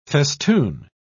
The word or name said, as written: festoon